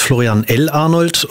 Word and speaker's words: Florian L. Arnold: Ja, mein Name ist Florian L. Arnold und betont man auf der ersten und zweiten Silbe.